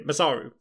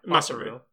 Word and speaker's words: Masaru: Yeah, okay, maybe that's more of a Masako? I never say his name. Masaru, sorry. – Masaru. – I never say his name out loud.